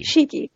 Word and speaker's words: Shikhee: Shikhee.